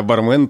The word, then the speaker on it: бармен: Но в данном случае бармен норма строга и требует от нас ударения на первом слоге.